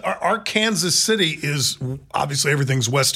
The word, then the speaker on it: Arkansas: And that's and it's pronounced Arkansas, not Arkansas.